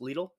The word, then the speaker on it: Lidl: Not, well, Little. Little's nice. Lidl? Is that how you say it? – Yes, that's that.